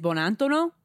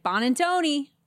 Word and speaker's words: Bonantono: The proper pronunciation for my last name is Bonantono, but we all say like Bonantoni.